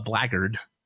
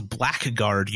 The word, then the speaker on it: blackguard: It was like a lawful good paladin who is now a blackguard. Yeah. That's right. That's listeners. – Mm-hmm. – What you've been reading as a blackguard your whole lives is actually pronounced a blackguard.